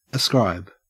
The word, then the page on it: ascribe: Australian English